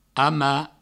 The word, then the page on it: amar: ama